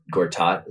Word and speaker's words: Gortat: I think the only one I know is Gortat.